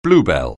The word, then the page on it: bluebell: anglais